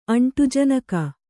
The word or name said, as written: ಅಂಟುಜನಕ